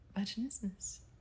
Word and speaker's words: vaginismus: Vaginismus.